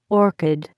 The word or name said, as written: orchid